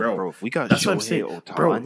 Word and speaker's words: Ohtani: Ohtani.